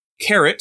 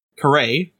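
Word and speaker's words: caret: Now you have your carrot, sometimes improperly pronounced as caray, apparently.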